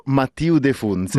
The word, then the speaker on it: Mathieu De Funès: Come si pronuncia? – Tu hai detto giusto, Mathieu De Funès è il suo nome.